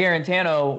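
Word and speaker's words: Guarantano: Jarrett Garantano, which I just figured out that's the correct way to say his name. He waited 3 years to correct us.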